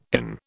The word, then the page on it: IN: British English